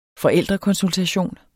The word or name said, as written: forældrekonsultation